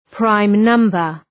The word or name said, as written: prime number